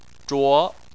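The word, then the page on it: zhuo: tone 2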